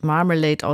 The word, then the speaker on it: Marmalade: Marmalade waren das, so würde ich es erstmal aussprechen, habe aber jetzt gesehen, es wird Marmalade ausgesprochen, wie das englische Wort für Marmelade, also die Orangenmarmelade, aber in zwei Worten geschrieben, Mar, also wie das Meer eigentlich auch, und Malade, Sie kennen vielleicht auch den Ausdruck, ich fühle mich heute ein bisschen Malade.